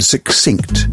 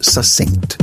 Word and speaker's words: succinct: It is quite common to hear the word succinct pronounced succinct. KS becomes SS. Succinct to succinct. This is technically an error, but it's one which, like a lot of other pronunciation errors, has a plausible explanation.